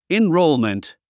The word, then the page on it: enrollment: inróulmənt; インローゥメント